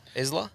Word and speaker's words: Isla: Isla? – That's Isla to me. – Isla, I think. I think the S is silent, isn't it?